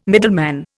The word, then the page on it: middleman: Indian